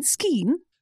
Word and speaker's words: Skeane: I'm pronouncing that right. Skeen? – Yes.